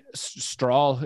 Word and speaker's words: Strahl: Strahl, how would you say that? You guys probably have more of a Norwegian influence in Minneapolis than we do in Wyoming. – Strahl is how I would say it.